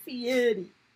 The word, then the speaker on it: Fieri: Is it Fiore or Fieri? How do you pronounce his name? – Cake Boss? – Not Cake Boss. I know his name. Guy Fieri.